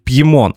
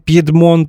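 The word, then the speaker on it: Piedmont: Дело в том, что в Италии есть горы с таким названием, но когда мы говорим об английском слове Пьедмон, то все же оно произносится именно так, потому что у него французское происхождение.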